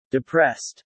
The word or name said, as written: depressed